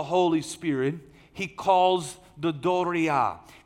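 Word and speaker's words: Doria (δωρεά): Doria. It's spelled D-O-R-E-A. In Texas, we can say it, the doria. But it's sounded out, Doria.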